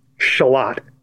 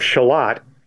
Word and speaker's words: Charlotte: For one thing, takes place in Shalott, which now, is spelt like Charlotte.